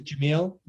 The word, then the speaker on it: Jamil: So my first name is Jamil, J A M, like Michael, mm-hmm.